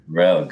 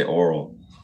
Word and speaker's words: Orell: Orell, good, yes. – And yeah, nice to meet you, Ryan. – It's good to meet you. Most people say Oral.